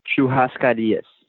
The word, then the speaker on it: churrascarias: So next we cut to, how do you pronounce this? Churrascarias restaurant? – Churrascarias.